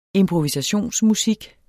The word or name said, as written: improvisationsmusik